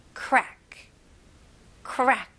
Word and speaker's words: crack: 네, 외래어로 이제 crack 이런 식으로 많이 발음하시잖아요. 뭐 crack이 있다 이런 식으로. 근데 영어로는 이름절로 발음하셔야 됩니다. crack, crack. 그래서 최대한 연결해 주시면 좋아요.